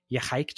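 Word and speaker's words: Ya'Xaik: The trail is called the Yahike Trail, and that's spelled Y-A apostrophe X-A-I-K,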